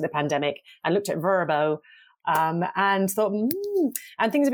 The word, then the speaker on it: Vrbo: Vrbo, as we spoke, you're supposed to pronounce it like a word. It's very pleasant. It's like Brno, isn't it? – This sounds like, I'm in the West Country.